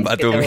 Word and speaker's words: Batumi: El jugador que viene del Dinamo Batum, como se llame, Batumi.